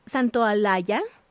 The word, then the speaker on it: Santaolalla: Esta compilación la hizo Gustavo Santaolalla. Ajá, sí lo dije bien. Santo, no, Santa o la... Es difícil.